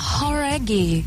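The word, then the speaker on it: Jauregui: É Lauren Horaghi. Talvez você já tenha ouvido falar dela, ex-integrante do grupo Fifth Harmony. – Horaghi, who é? – Horaghi. – Horaghi. – Ah, então a gente tava falando errado, cara.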